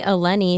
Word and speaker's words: Eleni: You can find me, Eleni Sovjo, on Twitter or Instagram at Electric Eleni. My name is spelled E-L-E-N-I.